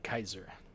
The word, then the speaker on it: Caesar: Alright, so the translator note here is that Caesar is actually pronounced in the Latin style. The indicating pronunciation is more like Kaiser.